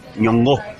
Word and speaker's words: Nyong'o: Nyong'o, bien pronunciado.